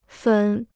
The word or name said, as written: 粉